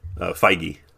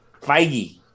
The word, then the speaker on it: Feige: And that's why I think, how does he pronounce his last name again? Feige? – Feige. – Feige.